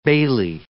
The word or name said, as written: bailie